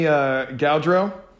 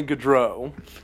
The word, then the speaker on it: Gaudreau: Yeah, so it's Johnny Gaudreau and Matthew Kachuk. – You clearly just looked it up, because that's not how you pronounce it. It's Matthew Kachuk and Johnny Gaudreau, also known as Johnny Ham and Cheese.